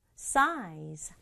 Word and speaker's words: size: 근데 우리말은 size 세 박자지만 영어는 size. size 한 박자로 발음한다.